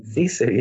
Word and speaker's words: sisu: Sisu, yeah.